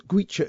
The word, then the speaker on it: Goitse: Gwecha is spelt G-O-I-T-S-E.